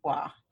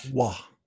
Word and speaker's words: Waugh: So it looks like Roy. Last name looks like Roy, but pronounced Wah. – Wah.